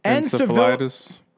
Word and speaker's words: encephalitis: She was diagnosed with, encephalitis. I literally put how to pronounce this, and I still can't pronounce it. – Encephalitis.